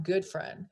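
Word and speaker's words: Gutfreund: And I was like, John Gutfriend. And I said it because I read it through the annual report. He goes, it's John Goodfriend. And he said, that's how you pronounce it.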